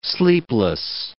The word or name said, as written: sleepless